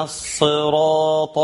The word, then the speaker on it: الصراط: ثم اهدنا الصراط، بتنطق بايه؟ بتنطق بالسين، حد نطقها بالسين، نقول له ايه؟ نقول له انت حرفت، انت شلت حرف الصاد وحطيت حرف السين. حفص نطقها بالصاد، وان كان ممكن يجي لي قراء تانيين نطقها بالسين، ده من فضل ربنا سبحانه وتعالى ورحمته ايه الواسعه على عباده.